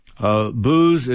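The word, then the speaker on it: Boos: Booz is not spelled like the alcohol, but it's like you're booing somebody. It's Baker Oscar Sierra.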